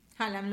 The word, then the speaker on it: Haaland: Haaland, ¿lo dije bien? – Sí, está bien.